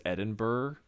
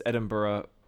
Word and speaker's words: Edinburgh: We would pronounce it as like just Americans that don't know as Edinburgh or something like that, but it's Edinburgh, Scotland.